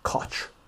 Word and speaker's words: Koch: The kosh there was pronounced like scotch, but without the S. Koch.